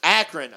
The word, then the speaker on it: Akron: But we got how do you pronounce this one? – Akron. – I knew I was saying it wrong.